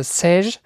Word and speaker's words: Seiche: also das, man schreibt das Seiche, wird aber, glaube ich, mhm. französisch ausgesprochen, also Sage, eine mhm.